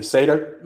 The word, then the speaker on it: Seider: Moritz Seider? – Oh, yeah. – That's his name, right? – I think it's technically Seder. – Moritz, like a Jewish dinner Seder during Passover.